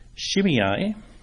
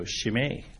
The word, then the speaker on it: Shimei: And that person's name is Shimei. That's the English pronunciation. Interestingly, when I was looking it up on the internet, there's a Hebrew pronunciation. So I thought, for Peter's benefit, the pronunciation in Hebrew is just the EI becomes a long A sound. So Shimei.